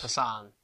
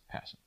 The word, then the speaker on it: Passan: I pronounce it like, you know, the Volkswagen Passat? – Yes. – Passan. – Passan. – Passan? – Yes. Finally, I'm correcting you for once.